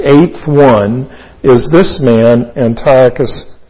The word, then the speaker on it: Antiochus: This is a prophecy of a man whose name was Antiochus Epiphanes. And Antiochus is A-N-T-I-O-C-H-U-S. A-N-T-I-O-C-H-U-S. His second name is Epiphanes. E-P-I-P-H-A-N-E-S. E-P-I-P-H. A-N-E-S.